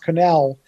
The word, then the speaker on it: Connell: And his name, oddly enough, is pronounced Connell.